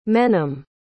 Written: menem

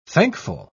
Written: thankful

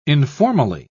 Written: informally

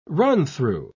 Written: run-through

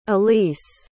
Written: elyce

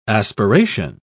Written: aspiration

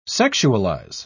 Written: sexualize

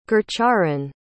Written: gurcharan

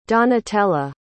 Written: donatella

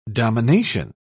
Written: domination

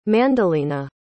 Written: Mandelina

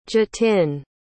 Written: jatin

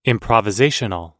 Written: improvisational

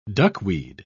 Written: duckweed

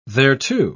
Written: thereto

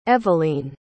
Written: eveleen

